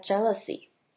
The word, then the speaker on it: jealousy: One possibility is that jealousy. 接着我们来看 jealousy 这个字。 jealousy 的发音比较特别，第一个特别的点是它的 ea 发成 a 的声音。